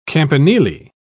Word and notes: campanile: British English

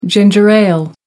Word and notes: ginger ale: American English

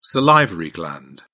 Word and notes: salivary gland: British English

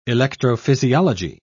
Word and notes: electrophysiology: British English

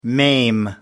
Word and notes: maim: American English

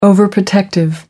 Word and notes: overprotective: American English

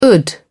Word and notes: d.ration: English; British English

